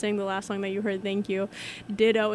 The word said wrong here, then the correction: Dido: and he was like, it's Dido, and make sure you don't get it wrong.